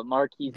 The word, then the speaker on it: Marquise: And I also, I'm going to say his name wrong. I always do, and I feel horrible about it. So... correct me when I say it wrong. I don't even want to say it. Mark. – If I can say it right. – Mark. I can't even say his first name right. The freaking 49ers receiver. – Oh, Marquise.